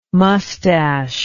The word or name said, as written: moustache